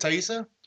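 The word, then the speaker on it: Taissa: Fortin and Joshua John Miller, starring, I think it's Thaisa Farmiga? Is that how you say it, Thaisa? – Yeah.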